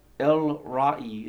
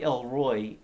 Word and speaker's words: El Roi: Here in Genesis 16:13, Hagar uses the name El Roi. It looks like El Roy, E L space R O I, El Roy, but it's actually pronounced Roi, El Roi, which means the God who sees.